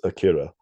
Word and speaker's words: Akira: I should just pronounce it, not like I'm trying to sound impressive or something. Just most people just know the pronunciation as Akira.